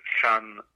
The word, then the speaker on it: Phan: Ici, avec sa sœur Yasmine, Fan Morissette, Fan, ça s'écrit P-H-A-N, et Morissette en bon québécois.